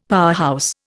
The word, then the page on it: powerhouse: Indian